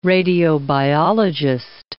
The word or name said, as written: radiobiologist